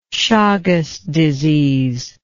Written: Chagas disease

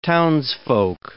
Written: townsfolk

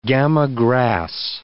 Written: gama grass

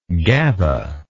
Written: gaba

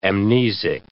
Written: amnesic

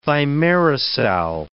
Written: thimerosal

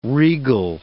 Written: regal

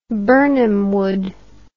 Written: Birnam Wood

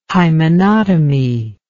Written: hymenotomy

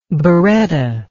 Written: beretta

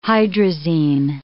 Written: hydrazine

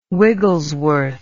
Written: Wigglesworth

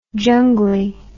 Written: jungli

